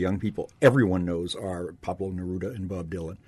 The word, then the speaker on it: Pablo Neruda: The number one was the Latin American poet, Pablo Neruda.